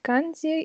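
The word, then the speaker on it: канзи (漢字): Вот говорят, что сначала изучаете японский, вот эти две азбуки, а потом начинаете изучать канзи, или не знаю, как правильно да, произносить. Да, это, это, это правильно, это правильно.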